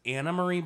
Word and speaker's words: Anne Marie: Who were like, she tried to tell people how to pronounce her name. So it's Anna Marie, but it's spelled Anne Marie.